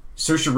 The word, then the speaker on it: Saoirse: I watched last night's Tonight Show this morning while I was doing stuff, and Saoirse Ronan was on for... – You nailed the pronunciation.